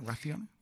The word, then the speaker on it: raciones: and then you'll probably get two or three raciones. Am I saying that right? Raciones? – Sí, raciones or platos.